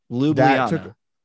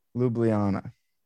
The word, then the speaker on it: Ljubljana: but to pronounce Ljubljana. – Pretty close. Ljubljana. Ljubljana.